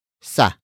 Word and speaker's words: sa: After that, we have sa.